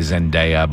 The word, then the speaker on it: Zendaya: And I know it sounds weird to say Zendaya, but that is how you pronounce her name.